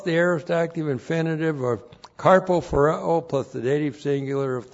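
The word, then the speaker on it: karpophoreō: K-A-R-P-O P-H-O-R-E long O. Carpo phareo.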